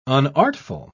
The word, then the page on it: unartful: British English